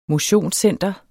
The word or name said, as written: motionscenteret